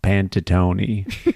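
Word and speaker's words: Panettone: Pantanine off the table. – Fairy balls done. – I guess it's actually pronounced Pantatoni.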